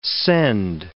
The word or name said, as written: send